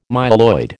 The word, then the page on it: myeloid: American English